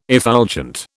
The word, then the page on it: effulgent: British English